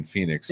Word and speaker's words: Phoenix: Uh, y'all don't pronounce the H in Phoenix.